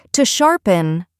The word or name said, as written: to sharpen